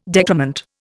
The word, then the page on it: decrement: Indian